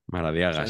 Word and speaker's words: Maradiaga: ahí es donde me pongo nerviosísimo porque no sé pronunciarlo, es Maradiaga. – Maradiaga, sí. La, D está cambiada con la R y eso me genera un, como un cortocircuito que, que me hace que me caiga mal el primitivo.